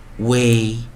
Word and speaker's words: weigh: way way way e i g h t 我们念 eight eight eight 首音 f r fr fr fr way freight freight freight wait wait wait 这个就是让同学们有一个概念了，在有时候我们在 e i 的时候，就 variant vowel 啊破音或者我们讲说特例的时候，发出长元音 a，然后的几个发音，那么在最后再来带一遍呢。 weigh, weigh, weigh.